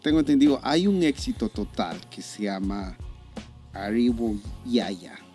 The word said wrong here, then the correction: Arigüeyaya: No sé si lo dije bien. – Arigüeyaya, sí, es...